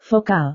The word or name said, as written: vokal